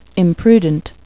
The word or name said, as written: imprudent